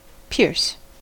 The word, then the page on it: pierce: American English